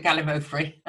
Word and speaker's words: Gallimaufry: And I wonder if you can just explore that a bit about the national curriculum because in your book, tell me if I'm right if I'm pronouncing this wrong, Gallimofree or Gallimo Gallimaufry. – I pronounce it Gallimaufry.